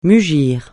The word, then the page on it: mugir: francais